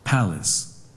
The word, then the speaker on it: palace: Palace. – One more time. – Palace. – Everybody together, 3, 2, 1. – Palace. – I put the pink color in the first syllable.